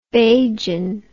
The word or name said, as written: bajan